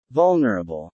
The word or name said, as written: vulnerable